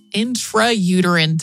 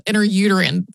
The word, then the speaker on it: intrauterine: Today, it is a history of the intrauterine device is how it's technically pronounced, but it always is going to come out of my mouth, interuterine, sort of slurred together probably.